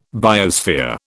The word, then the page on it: biosphere: British English